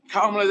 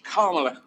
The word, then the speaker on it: Kamala: and he is a more senior gentleman, but Kamala, that by, by the way, you have to, we worked on this, you have to emphasize the first syllable of her name. It's Kamala. – Oh, Kamala. Okay. – Oh, it's Kamala. Kamala, like Kamala. – Got you. – Yeah, Kamala. Kamala. Put a dash after the A, Kamala.